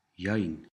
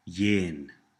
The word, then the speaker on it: yain: Yain. Yain. Say again. Cold. Yain. Yain. Some people pronounce this word yin. Yin. But in this course, we will use yain.